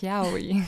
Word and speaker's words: Yaoi: Ja, richtig, Yaoi.